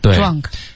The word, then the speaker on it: drunk: 啊，drunk。 – 这个 z 的音，dr 的音，不是一个 z 的音，OK？